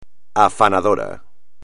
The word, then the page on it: afanador: espagnol